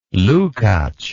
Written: lukacs

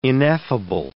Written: ineffable

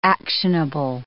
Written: actionable

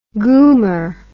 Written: goomer

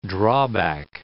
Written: drawback

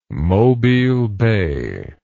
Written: mobile bay

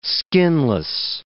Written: skinless

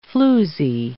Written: floozy

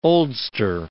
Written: oldster

